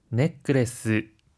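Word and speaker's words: ネックレス: 하이, 넥크레스 브레스렛도라고 할 때는 중간에 초금이 들어가는데요. 한 박자 쉬었다가 발음하는 점 주의해 주세요.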